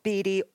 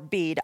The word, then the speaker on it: Bede: Then there's this other group that says, that whole etymological theory actually begins with Bede or Bede. I've heard his name pronounced both ways, but that's not really where it comes from.